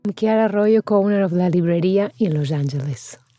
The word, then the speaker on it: La Librería: La Librería.